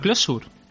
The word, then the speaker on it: Clojure: Se escribe Clojure, ¿no? Pero no se pronuncia así. Sería más a Closure.